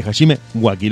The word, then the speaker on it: Hajime Waki: Hajime Waki. ¿Lo pronuncié bien? Así es la pronunciación más española aproximada.